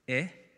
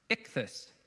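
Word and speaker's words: ichthus (ἰχθύς): It's pronounced, this is ichthus, ichthus.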